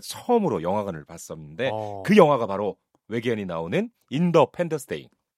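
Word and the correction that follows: Independence Day: 인디펜던스 데이. – 예. 독립 기념일. – 네. 아, 방금 정정해 주셔서 너무 네.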